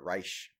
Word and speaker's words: Reich: Robert Reich. No, that's how you pronounce it, I believe.